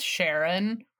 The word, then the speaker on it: Charon: And I just love that it's pronounced Charon.